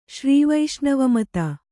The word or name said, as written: ಶ್ರೀವೈಷ್ಣವಮತ